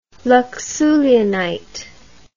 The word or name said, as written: luxullianite